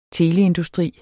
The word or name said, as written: teleindustri